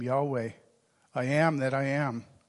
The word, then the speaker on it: Yahweh: And the first is a Hebrew word, which we pronounce Yahweh, or some people will say Jehovah.